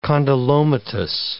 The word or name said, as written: condylomatous